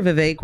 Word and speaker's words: Vivek: Do you remember Vivek Rhymes with Cake? – I do indeed.